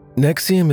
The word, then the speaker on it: NXIVM: Keith Raniere, the founder of a criminal sex cult called Nexium, has been sentenced to 120 years in prison for sex trafficking and fraud. This years-long legal battle finally came to an end on Tuesday. – Nexium is spelled N-X-I-V-M.